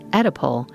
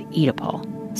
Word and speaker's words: Oedipal: By the way, the American pronunciation is Oedipal, and the UK pronunciation is Oedipal. Some people use both.